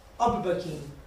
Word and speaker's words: overbooking: Overbooking, como dice en inglés. Diz lá. Overbooking. Pronuncia ben. A ver. Pronuncia ben o overbooking ese que estou dicindo mal. – Overbooking? – Ok, vale. Moito mellor.